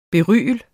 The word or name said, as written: BERYL